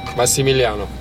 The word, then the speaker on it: Massimiliano: Massimiliano.